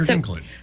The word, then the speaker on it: Dinklage: Yeah, Peter Dinklage.